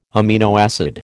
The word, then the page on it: amino acid: American English